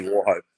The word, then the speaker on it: Wauchope: It's pronounced Wauchop, isn't it? Wauchop or Wauchope? Wauchope.